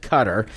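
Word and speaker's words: Qatar: Well, last week we had Cutter, and that is the way you pronounce it, Cutter.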